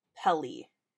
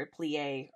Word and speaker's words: Pelée: Silberis worked as a common laborer in the capital city of St. Pereire, close to the volcano Mount Pelée. I believe is how you say it, or a plie, or something like that. – How is it spelled? I think it's P-E-L-E-E. – P-E-L-E. I think it's Pele. – Pele?